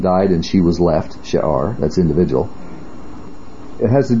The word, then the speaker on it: She'ar: This is the term She'ar, like we saw in Isaiah 10, the word for remnant.